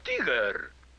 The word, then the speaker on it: Tigger: Mi nombre es Tigger. T-I-W-G-R. Se pronuncia Tigger.